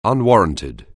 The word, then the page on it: unwarranted: anglais